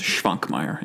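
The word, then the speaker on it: Švankmajer: I'm not going to try to say Schwankmeyer. – Well, and check the S that has a little carrot on top. That's a sh sound, so it's Schwankmeyer.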